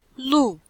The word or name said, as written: lu4